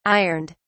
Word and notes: ironed: British English

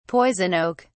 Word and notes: poison oak: British English

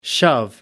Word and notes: shove: American English

